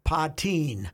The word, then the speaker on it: Puttin: And her last name is spelled P-U-T-T-I-N. – No. – P-U-T-T-I-N. – Uh-oh. – So Putin. I checked. – Putin-tang. – She pronounces it Patine.